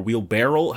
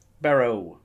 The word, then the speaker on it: wheelbarrow: Is it wheelbarrow or wheelbarrel? How the fuck? – Barrow. – That's dumb. – It's got a W on the end.